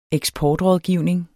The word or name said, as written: eksportrådgivning